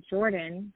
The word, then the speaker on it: Jordynn: My name is Jordynn, but it's spelled J O R D Y N N.